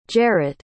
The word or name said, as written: jaret